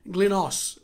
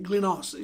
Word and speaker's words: Glynos: And the way they would pronounce it is Glynos, which is actually technically Glynsos. All right, it's G-L-Y-N-T-Z-O-S. But the way my mates would pronounce it is just Glynos, just like G-L-Y-N-O-S.